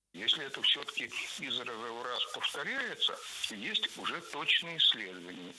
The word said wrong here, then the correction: из раза в раз: Из раза в раз. Здесь в этом выражении ударение поставили на предлог. Но это неверно. Должно быть из раза в раз. Предлог оставляем безударным.